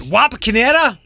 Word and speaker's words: Wapakoneta: El Presidente was in the area yesterday at Wapakoneta.